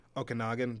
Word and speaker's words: Okanagan: How do you pronounce that? – Okanagan? – Okanagan. There we go.